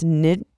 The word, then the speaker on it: Nijmegen: Nijmegen.